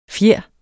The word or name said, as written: fjer